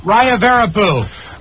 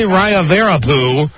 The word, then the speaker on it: Rayavarapu: Oh, how am I going to pronounce his last name? R-A-Y-A-V-A-R-A-P-U. – Raya Verapu. – Thank you. K. Raya Verapu from Rockville, Maryland, won yesterday's key.